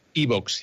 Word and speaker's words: iVoox: O iBox, se escribe I V O O X.